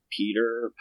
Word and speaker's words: Piter: Um, so in the audiobook I listened to, they pronounce it Piter. Okay. Uh, I I've also heard it pronounced like Peter, Pite, or something like that.